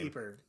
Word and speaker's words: Pieper: Pieper.